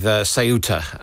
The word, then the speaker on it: Ceuta: Let's start with Ceuta, as I'm told it should be pronounced.